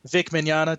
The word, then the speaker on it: Vic Mignogna: the one that we have with Vic Mignogna, did I say that right? – Yes, you did.